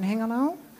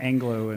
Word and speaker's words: Hengelo: So last year you were also in Hengelo. – And I still couldn't figure out how to pronounce Hengelo. I had to practice Hengelo, Hengelo. – Hengelo. – Yeah, I kept saying Anglo and yes.